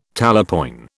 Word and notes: talapoin: British English

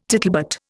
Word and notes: tittlebat: Indian

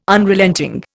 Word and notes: Unrelenting: Indian

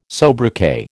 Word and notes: sobriquet: American English

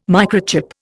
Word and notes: microchip: Indian

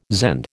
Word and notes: Zend: American English

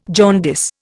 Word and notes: jaundice: Indian

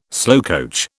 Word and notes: slowcoach: British English

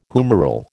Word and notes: humeral: American English

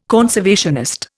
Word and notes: conservationist: Indian

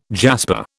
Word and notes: jasper: British English

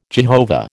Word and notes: Jehovah: American English

